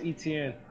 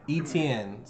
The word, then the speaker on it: Etienne: How do you say his last name? – Travis Etienne. – Etienne.